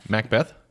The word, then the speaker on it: Macbeth: Macbeth? That's how it's pronounced. – Macbeth. – M-A-C. – Macbeth. – B-E-T-H. – Macbeth.